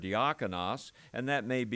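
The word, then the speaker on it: diakonos (διάκονος): The word deacon, diakonos, sounds like deacon, because basically when we say the English word deacon, we're pronouncing the Greek word diakonos.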